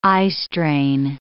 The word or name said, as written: eyestrain